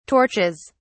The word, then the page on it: torches: British English